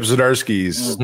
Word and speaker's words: Zdarsky: Zdarsky, is that how you say it? – Yeah, Chip Zdarsky's Daredevil.